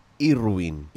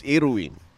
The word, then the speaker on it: Irwin: Ito'y Irwin. – Irwin. Can you spell that, please? – India. – Uh-huh. – Romeo. – Okay. – Weng Weng. – Buiset, India, and Nancy. Yan. So, it's Irwin.